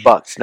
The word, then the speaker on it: bucks: Bucks, not books. – To one, you said it right.